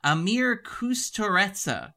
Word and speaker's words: Emir Kusturica: It is Emir, and I want to get this right because I did actually check the pronunciation, Emir Kusturica, 1988, his third film, Time of the Gypsies.